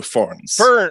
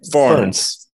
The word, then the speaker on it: Fearns: It's like the plant, but plural. So Fearns. – Say it again. – Yeah. Fearns.